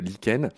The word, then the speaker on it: lichen: Donc ça s'écrit lichen, hein, mais, mais on, dit bien euh, lichen.